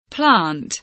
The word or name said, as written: plant